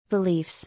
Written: Beliefs

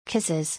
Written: Kisses

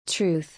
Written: Truth

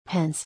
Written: Pens

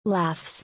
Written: Laughs

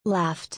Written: Laughed

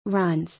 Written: Runs

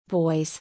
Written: Boys

